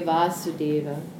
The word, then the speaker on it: Vasudeva: And it should actually be a long A, Vasudeva. – And Vasudeva is, so Vasudeva with the short A is Krishna's father.